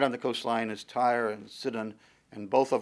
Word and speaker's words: Tyre: Ezekiel 27 as well as 26 describes the city of Tyre, the way that we would pronounce it. Actually, probably in the Hebrew, it's pronounced Sor.